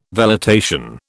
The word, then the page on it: velitation: British English